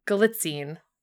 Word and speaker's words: Galitzine: And then it's starring Taylor, Zakara Perez, Nicholas, God, Galitzine. I've heard them pronounce their names many Galitzine? – Times. Galitzine? Galitzine. Thank you.